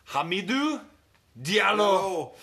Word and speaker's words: Hamidou Diallo: I always F up the pronunciation of his name, but it's Hamidou Diallo.